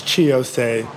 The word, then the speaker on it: Chi Ossé: We have Mr. Chi Ossé. Did I pronounce that correctly? – Is he on? – It's Chi Ossé.